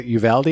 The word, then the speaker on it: Uvalde: Is it Uvalde? Is that how you pronounce it? – I think it? – So. It is.